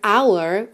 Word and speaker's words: hour: Ah, e claro, não esqueçamos que essa palavra hour ou aula é uma das exceções aí em que a letra H não é pronunciada.